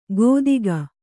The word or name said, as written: ಗೋದಿಗ